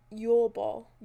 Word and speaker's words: Yule ball: How do you say it? – Like Yuletide? – Yuletide. – Yule ball.